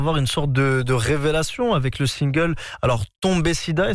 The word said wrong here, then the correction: Tombé Siba: est-ce que je le prononce bien? – Tombé Siba, et qui arrive en 2020 et qui cumule plusieurs millions de vues sur les plateformes de stream.